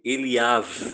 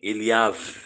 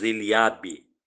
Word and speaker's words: Eliav: Logo que chegaram, assim que Samuel colocou os olhos sobre Eliav. Para quem quer saber a pronúncia, é com o som de V, de vaso, tá bem? Eliav. Em português nós falamos Eliabe, com som de B no final.